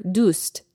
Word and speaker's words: دوست: With both phrases, to say them properly in the manner in which they are written, you would pronounce the T in the word dust. So, dustet doram and "dusam dori. But in conversation, the second T gets dropped, so it sounds like دوست دارم and دوسم داری.